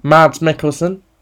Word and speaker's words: Mads Mikkelsen: Mads Mikkelsen.